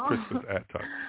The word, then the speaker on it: Crispus Attucks: Yeah, I mean, even when you think of, I don't know if I'm pronouncing this right, but Crispus Attucks, was the first person really to die in the Revolution. Yes, there you go. – Crispus Attucks, yeah.